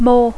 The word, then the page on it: more: English